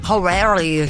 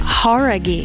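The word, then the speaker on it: Jauregui: É Lauren Horaghi. Talvez você já tenha ouvido falar dela, ex-integrante do grupo Fifth Harmony. – Horaghi. Who é? – Horaghi. – Horaghi. – Então a gente tava falando errado, cara.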